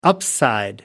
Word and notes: upside: American English

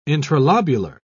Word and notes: intralobular: British English

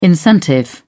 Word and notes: incentive: British English

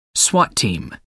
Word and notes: swat team: American English

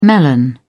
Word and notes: melon: British English

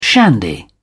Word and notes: shandy: British English

